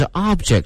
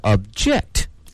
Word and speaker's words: object: 聊完了名词哈，它的发音是 object，接下来我们看看它的动词，动词发生了一个变化，语音上啊，这个发音上发生了一个重大的变化，因为它的重音从 object 往后移了，变成了 object。